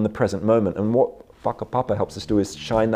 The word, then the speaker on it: whakapapa: It's a bit like, you know, in Maori culture, there's this concept of whakapapa, spelt with a WH, though pronounced WH is pronounced with an F in Maori.